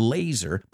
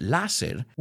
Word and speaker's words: laser: And I know you recognize the word laser, but we don't pronounce it láser. We say laser. Laser precision.